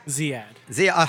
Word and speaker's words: Ziyad: Anyway, and finally, Hari Zayed. Did I say it right? – Ziyad. – Hari Ziyad. You know, Rashida Tlaib is spelled T-L-A-I-B, and everybody calls it Talib, but I'm sure it's Tlaib.